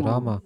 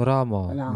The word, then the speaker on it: Рама: У них нет никакого представления о том, кто такой Кришна, кто такой Рама, как они должны произносить. Естественным образом, Рама, не Рам. Рама. Это правильное произношение.